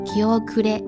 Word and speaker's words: 気後れ: 就是生气的气，加一个后面的后的繁体字，加上假名的 le，念作念作 kiokure kiokure，它的意思是胆怯胆怯的意思。